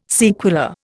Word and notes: sequela: Indian

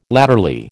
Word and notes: latterly: American English

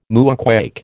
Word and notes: moonquake: American English

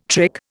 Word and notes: trick: Indian

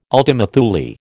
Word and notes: ultima Thule: American English

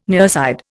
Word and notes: nearside: Indian

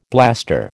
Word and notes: blaster: American English

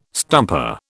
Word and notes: stumper: British English

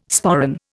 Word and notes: sporran: Indian

